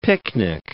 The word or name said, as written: picnic